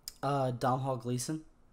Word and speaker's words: Domhnall Gleeson: Domhnall Gleeson.